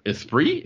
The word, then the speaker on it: Esprit: Esprit? It's Esprit? Is that how you pronounce it? – Yeah, it's Esprit.